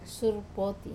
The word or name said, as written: surpoti